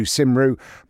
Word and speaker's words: Cymru: But it's spelt Cymru, which is the Welsh spelling, which is C-Y-M-R-U. So it looks like Tattoo Cymru, but it's actually Tattoo Cymru.